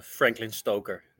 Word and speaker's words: Franklin Stoker: Yeah, it's Franklin Stoker.